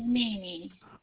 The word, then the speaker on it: Mimi: And my name is Mimi. – Oh, that's a beautiful name. How do you just spell it? – M-I-M-I.